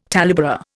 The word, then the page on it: talebearer: Indian